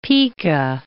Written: pika

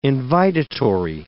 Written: invitatory

